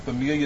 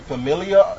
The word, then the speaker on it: familia: And so those with familiar spirit, familiar is like, almost like how Spanish people say family. How do you say it? – Familia. – Yep, see? Familia, say it again.